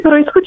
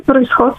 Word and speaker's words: произход: Думите произход и произход са дублети, т.е. независимо къде ще поставим ударението, ще бъде съобразено с нормата. Първоначално се е казвало само произход, но може би под влияние на думата изход е започнало все по-често да се чува произнасянето произход.